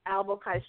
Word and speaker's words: Albokai: Well, you may find me on Facebook, of course, Janice Montgomery Albokai Strauser.